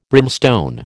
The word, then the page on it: brimstone: American English